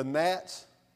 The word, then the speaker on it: gnat: Has anybody ever been fishing in the marsh and the gnats? We call them gnat. I don't know why the G is silent.